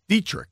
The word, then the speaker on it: Deatrich: How would you say this? D-E-A-T-R-I-C-H. Dietrich, right? Dietrich, yeah.